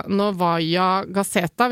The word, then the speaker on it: Novaya Gazeta: Og det som sier egentlig det meste, er jo da at um, den uavhengige avisa Novaya Gazeta, hvis jeg uttaler det riktig Det er riktig.